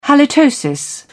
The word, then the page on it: halitosis: British English